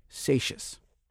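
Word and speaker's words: Seixas: And one of those 24 is this man, Vic Satius. – Rhymes with gracious. It's like the word spacious without a P.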